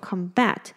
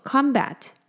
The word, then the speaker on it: combat: 那有一个很有趣的事情，就是 combat 这个字呢，它其实还可以当作名词，而且它当作名词的时候呢，它的重音会改变，因为当我们把它当动词用的时候呢，它念作 combat，所以它的重音在后面的 bat。但是呢，如果我们把它当名词的话呢，它的重音会变到前面的 com 的部分，所以会念作 combat。